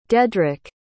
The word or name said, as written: dedrik